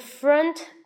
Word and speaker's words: front: 再来一个词呢，奇怪的词，在前面，so usually in front，到底是 in front of，in front of，你到底怎么读呢？要疯掉。那这个 o 在这里发的是呃。